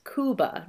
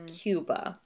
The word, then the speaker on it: Cuba: he mispronounces the name of the country in a way that no native would, especially one with a thick accent. Mm-hmm. So, the pronunciation in Spanish is Cuba, not Cuba.